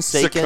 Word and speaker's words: Saconn: Hello, live and on tape from lovely Sacon Drive, S-A-C-O-N-N. Is that how you say it? – I think so. Sacon, Sacon.